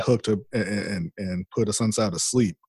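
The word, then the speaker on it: Assunção: I'm not sure exactly how to say it, Asuncao. I believe that's how you pronounce it.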